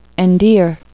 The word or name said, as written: endear